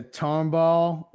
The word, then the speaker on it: Tomball: Isn't New Orleans closer to Tomball where Jimmy's from? Am I saying that correctly? – It is. Well, it's close enough.